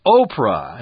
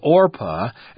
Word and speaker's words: Orpah: Many people constantly mispronounced her name as Oprah instead of Orpah, and the name Oprah stuck.